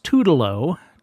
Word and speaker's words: Toodalo: But Ellington himself apparently pronounced it Toodalo and claimed it was misspelled on early recordings.